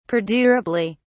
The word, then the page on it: perdurably: British English